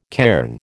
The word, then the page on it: cairn: American English